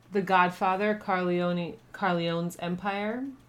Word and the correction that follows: Corleone: Corleone. – Yeah. Is that how you say it? – Yeah.